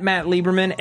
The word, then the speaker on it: Matt Lieberman: I'm at 123Jackie_B, and that's Jackie spelled J-A-C-Q-U-E. – Yes, and you can tweet me at Matt Lieberman, M-A-T-T-L-I-E-B-E-R-M-A-N.